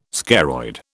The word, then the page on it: scaroid: British English